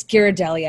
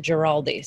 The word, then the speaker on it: Ghirardelli: Well, you're not, Robbie's mom pronounces it Giraldi. So we're all doing better than that.